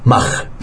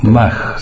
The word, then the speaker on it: Mach: Sí, Mach. Creo que se pronuncia. – Mach, sí. Te agradezco la corrección.